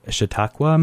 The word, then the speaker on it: Chautauqua: And this, the onset of the Glenwood Springs Community Concert Association in 1947 kind of came on the heels of the Chautauqua movement.